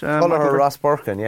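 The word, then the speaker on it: Tullogher Rosbercon: He's an Irish underage international who set aside his hurl and slitter in Kilkenny while playing for Tulloch Rosporcan. Is that the way you pronounce it? – Tulloch Rosporcan, home of Walter Walsh.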